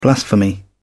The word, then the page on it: blasphemy: British English